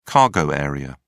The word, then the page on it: cargo area: British English